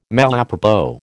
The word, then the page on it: malapropos: American English